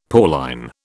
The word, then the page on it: Pauline: British English